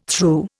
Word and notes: threw: Indian

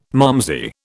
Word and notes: malmsey: British English

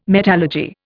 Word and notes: metallurgy: Indian

